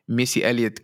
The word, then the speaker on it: Missy Elliott: voelt het alsof ik in een Missy Elliott clip zit. zeg ik het goed? Ja, volgens zeg ik het goed, man. voelt alsof ik in een, in een Missy Elliott clip zit als ik deze track luister.